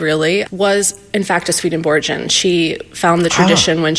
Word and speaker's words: Swedenborgian: I have so many questions, okay, as many people do, about what's Swedenborgian, is the right pronunciation. – Yes.